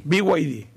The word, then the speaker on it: BYD: BYD, ahí estamos, bien.